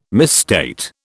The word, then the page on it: misstate: British English